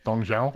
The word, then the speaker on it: Dong Zhuo: I would like to point out to you, that you did not pronounce that name correctly. – Tong Zhuo. – I was not trying to. – Did I pronounce it right? – That was closer. – Dong Zhao. – Thank you.